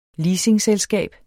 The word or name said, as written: leasingselskab